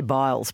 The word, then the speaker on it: Biles: How do you say her name? Sorry. – I say Biles.